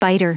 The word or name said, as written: biter